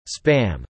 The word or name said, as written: spam